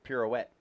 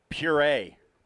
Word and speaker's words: pirouette: I'm not going to try to pronounce it, but it looks more like it's pronounced pirouette. – Pirouette. Yeah. – Not puree.